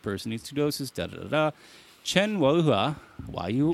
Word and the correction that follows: Chen Weihua: How do Chen, you say his name? – Chen Weihua.